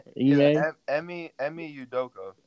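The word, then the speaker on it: Ime Udoka: Emi Udoka.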